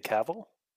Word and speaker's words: Cavill: But The Witcher is like Henry Cavill. Like I probably butchered his last name. Cavill. – I'd say Cavill. – Yes, that one.